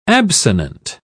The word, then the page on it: absonant: British English